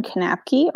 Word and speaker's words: Knapke: So you can always find me on LinkedIn, Katherine Knapke on LinkedIn. And last name is spelled K-N-A-P-K-E.